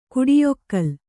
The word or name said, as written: ಕುಡಿಯೊಕ್ಕಲ್